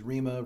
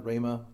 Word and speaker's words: rhema: The word rhema can be pronounced several different ways. I've heard preachers use rhema, rhema, but it, it's, it's basically like this.